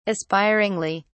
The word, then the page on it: aspiringly: British English